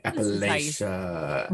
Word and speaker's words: Appalachia: And I wanted to tell you that I've seen a few posts lately with t-shirts and things talking about how to pronounce Appalachia. And it's like, it spells out and like puts the syllables on there, like Appalachia, so people can say it. And I was like, we were right about that one.